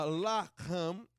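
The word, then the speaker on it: laham (לחם): Quando alguém que fala o hebraico fala uh, ah, laham, significa luta, mas com a mesma escrita, se ele falar lahem, significa pão.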